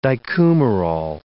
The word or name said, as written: dicoumarol